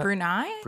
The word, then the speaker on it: Brunei: It is very popular throughout Indonesia, Malaysia, Singapore, Brunei. Is that how you pronounce that? – Brunei? – Brunei.